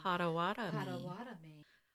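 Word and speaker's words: Pottawattamie: Pottawatomie. – Well, this one was built in 18... – sounds like a lobotomy. Pottawatomie.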